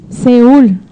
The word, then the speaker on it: Seúl: Seúl, no lo sé pronunciar, ¿sí se dice así? – Seúl, ok.